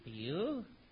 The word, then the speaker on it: Pugh: N-E-D-D-I-E. Neddy? What was next? – Neddy Pugh Seagull. – Pugh? P-H-E-W? – No. It's pronounced Pugh, but it's spelt Pug. – Pug, yes. P-U-G-H.